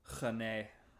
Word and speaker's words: Rennais: But Group E, Sevilla, Chelsea, FC Krasnodar, and Stade Rennais. I think it's how you pronounce it, or Rennes. – Rennais.